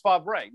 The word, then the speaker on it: Favre: And the third thing to you is, how the F does he get Brett Favre out of, I know this is the oft-asked question, out of Favre? It's Favre.